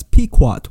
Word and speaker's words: Pequot: It is indeed pronounced as Pequot War, and it's not the same thing as the King Philip's War, which happened later on.